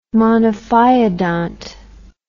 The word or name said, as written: monophyodont